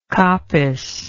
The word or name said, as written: coppice